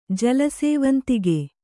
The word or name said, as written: ಜಲಸೇವಂತಿಗೆ